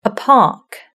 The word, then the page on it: a park: British English